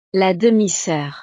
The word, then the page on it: la demi-soeur: frances